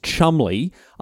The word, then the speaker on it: Cholmondeley: And don't get me started on bloody Cholmondeley, which they say is Chumley.